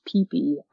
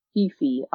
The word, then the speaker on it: Phi Phi: And it's spelled P-H-I-P-H-I, but it's actually pronounced Peepee Island, not Fifi Island.